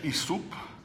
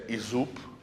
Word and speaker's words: ISUP: protokol ISAP. Někdo čte též ISUP, nejhorší výslovnost, kterou nemám rád a kterou používají Němci, je ISUP, že oni čtou S jako Z obvykle, ještě takovým německým oni řeknou ISUP, takovým opravdu zvláštním přízvukem, tak já asi říkám nejčastěji ISAP.